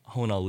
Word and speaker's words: Hoh: My last name, you know, I'm Tim Ho, H-O-H. – Okay. – And everybody pronounced my name wrong my whole life.